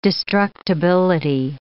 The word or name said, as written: destructibility